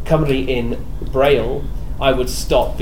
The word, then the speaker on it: Cymru: Because their speech says Saimru instead of Cymru, and there are lots of other words like this where Cymraeg.